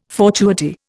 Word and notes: fortuity: Indian

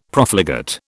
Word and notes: Profligate: British English

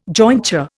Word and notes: jointure: Indian

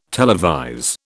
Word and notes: televise: British English

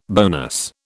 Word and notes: bonus: British English

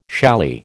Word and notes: challis: American English

